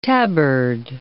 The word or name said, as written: tabard